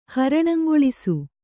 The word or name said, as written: ಹರಣಂಗೊಳಿಸು